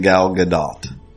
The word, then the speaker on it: Gal Gadot: Like, just say Gadot, but there should be, like, the ah should last longer, whatever. She was saying Gal Gadot. So, that's how it's supposed to be pronounced, supposedly.